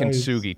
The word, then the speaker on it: kintsugi: You discuss in the book this ancient Japanese technique of kintsugi. – Yeah. – Is that how I pronounce it correctly? – Yes, kintsugi. Nice.